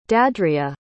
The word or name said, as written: deandrea